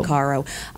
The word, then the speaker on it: Caro: Caro.